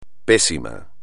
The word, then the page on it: pésimo: espagnol